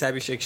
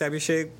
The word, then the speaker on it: Abhishek: It's Abhishek Shah.